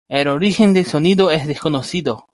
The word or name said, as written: desconocido